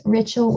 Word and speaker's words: Rachel: It's Rachel with an E.